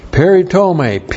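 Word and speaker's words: Peritome (περιτομή): And then the noun peritome. Peritome, P-E-R-I-T-O-M, long E. Peritome.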